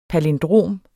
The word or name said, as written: palindrom